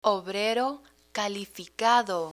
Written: obrero calificado

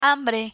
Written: hambre

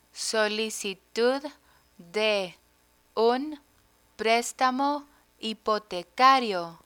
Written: solicitud de un prestamo hipotecario

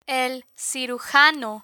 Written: el cirujano

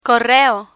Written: correo

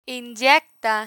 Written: inyecta

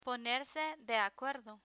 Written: ponerse de acuerdo

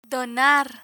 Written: donar

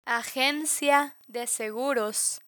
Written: agencia de seguros

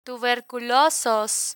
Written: tuberculosos

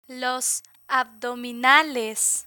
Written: los abdominales

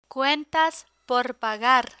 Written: cuentas por pagar

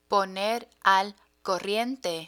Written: poner al corriente

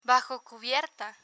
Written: bajo cubierta

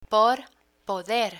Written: por poder

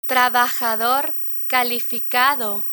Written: trabajador calificado

